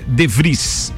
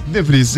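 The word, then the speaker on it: de Vries: a pronúncia dele pela origem do menino é DeVries. – DeVries.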